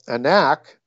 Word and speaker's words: Anak: So Anak is um, H um, 6061, and it's pronounced Anak, A W N A W K. So Anak.